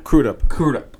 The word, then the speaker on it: Crudup: it's Billy Crudup, I believe. He played Dr. Manhattan. – Crudup, okay.